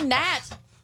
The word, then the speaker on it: gnat: Or like gnat. See, gnat. That's a time you're not supposed to say the G.